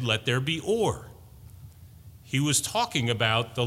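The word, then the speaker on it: or (אור): And it's not O-R-E, it's O-W-R, but you pronounce it like O-R-E, or.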